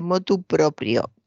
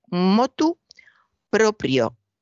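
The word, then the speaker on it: motu proprio: Lo corregía bien porque no se debe decir de motu propio, perdón, de motu propio. Se debe decir motu propio, sin la D, sin la preposición inicial, y propio, con PR en la segunda sílaba, motu propio Es una locución latina que significa literalmente con movimiento propio, libre y voluntariamente o por iniciativa propia.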